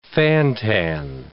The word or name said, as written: fan-tan